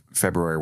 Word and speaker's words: February: Before we do any of that, I want to talk about how you, your just lovely pronunciation of February, where you lean into the R as you're supposed to.